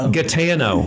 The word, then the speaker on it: Gaetano: tell me your last. I butchered it already. – Gaetano. – I mean, I'm looking at it right here. Gaetano, co-founder and CEO of Solace Vision. Did I get that right? – Yes.